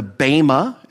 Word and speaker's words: bema: Well, they'll be evaluated at the judgment seat of Christ, the bema, as it's pronounced in the Greek.